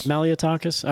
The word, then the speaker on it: Malliotakis: Also coming out of New York, and again, it's Representative Nicole Nicole Malliotakis. Am I saying that right? – Malliotakis.